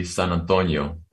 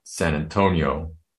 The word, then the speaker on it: San Antonio: This was the summer of 1979, and most of us living on the border called the city San Antonio, as it's pronounced in Spanish, and not San Antonio, as I've heard it pronounced in other places.